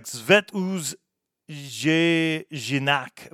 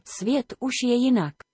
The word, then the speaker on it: Svět už je jinak: Here on 70, we're playing Sergos from their album, which looks like Zvet Uz Jinak, but I think that a lot of J's in other countries are like an H. So let's see what Google Translate says is the pronunciation. – Svět už je jinak. – Okay, yeah, a little bit different than what I said, but nonetheless, it translates to The World is Different.